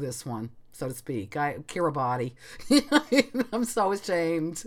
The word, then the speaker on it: Kiribati: It's spelled K-I-R-I-B-A-T-I. And again, not 100% sure, but I think it's Kiribati. – Yes.